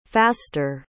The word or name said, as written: faster